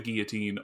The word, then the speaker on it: guillotine: Okay, so this track is called Drop the Guillotine, or if you want to pronounce it guillotine, like apparently the singer does. Yeah, it's either one.